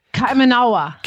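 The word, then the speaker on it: Kaimanawa: How do you say this? Kamawana was? – The Kamanawa. – Kamanawa horses.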